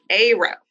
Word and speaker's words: Cairo: It's like the Egyptian city, pronounced Cairo. Oh. Cairo, Illinois. Cairo. Not Cairo. It's Cairo.